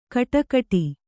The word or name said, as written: ಖಡಾಖಡಿ